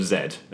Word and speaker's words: Zed: That, no, yeah, that Zed, is, Zed is a, yeah. the English pronunciation of Zed, and cool.